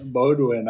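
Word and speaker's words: Bowdoin: We went to this college town many times, the Bedouin, or if that's how you say it. – Bodwin. – Yes, Bodwin.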